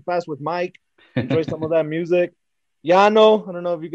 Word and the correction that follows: Llano: How do you say it? – It's Llano.